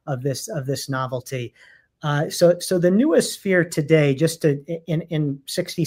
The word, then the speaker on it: noosphere: which is the concept of noosphere. That's how you pronounce it? – Yeah, and the noosphere or nuosphere, yes.